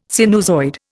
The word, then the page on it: sinusoid: Indian